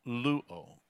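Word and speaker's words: luo (λύω): The Greek word for untie is the word luo. L-Y-O, luo is how it's pronounced.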